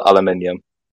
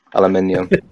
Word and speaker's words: aluminium: Yeah, because it's clearly spelled aluminium. Like, there's aluminum, there's an extra U in there that needs to be pronounced. Aluminium.